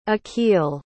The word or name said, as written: akil